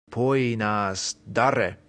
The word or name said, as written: poenas dare